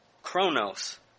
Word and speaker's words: chronos: It's a different focus than the Greek word chronos, which is also translated time in Scripture. Chronos is spelled C-H-R-O-N-O-S.